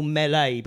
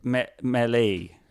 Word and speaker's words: melee: And I think you were confused because I think the word melee is written like it should be pronounced melee. It's a French word.